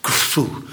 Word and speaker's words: Khufu: De f, niet met je tanden, met je lippen. De f, kufu, kufu, kufu. Begrijp je? Als je dat opschrijft en in Griekse letters, en dan moeten de Grieken het uitspreken, wordt het Cheops, want in het Grieks komt er altijd een S achter. Minstens een S komt erachter.